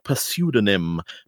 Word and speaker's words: pseudonym: On arrival, each is given a pseudonym drawn from, I know you don't pronounce the P, drawn from Cluedo before being introduced to the blackmailer.